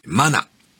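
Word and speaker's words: 真名: 略字でありますところから、かなと名付けられまして、マナ、真実の真に名前と書いてマナでありますが、はい、それに対してこれは略字ですよという意味で、かな、仮の名とされたわけであります。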